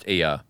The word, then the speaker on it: ER: It's pronounced ER.